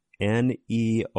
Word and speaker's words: Neomie: So Neomie.